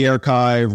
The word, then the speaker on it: Airchive: And uh, where do we find the Airchive? Well, first off, thank you for pronouncing it correctly because it is spelled, people sometimes people call it the air chive like an onion, but I can tell you it, it doesn't smell.